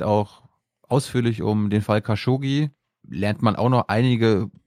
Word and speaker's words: Khashoggi: für nicht nur Khashoggi, Khashoggi wird da ausgesprochen, weiß ich inzwischen, also nicht nur für das, sondern sondern für den Background äh, von MBS.